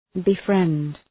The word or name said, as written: befriend